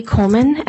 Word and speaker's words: Coleman: My name is Keely Coleman, and my last name is spelled C-O-L-E-M-A-N.